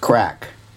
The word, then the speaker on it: craic: And just to clarify something for you, when he says the crack was good, that is Irish slang, spelled C-R-A-I-C, pronounced crack, which, broadly speaking, means fun, entertainment, conversation, gossip, good times, crack.